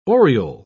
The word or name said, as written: oriole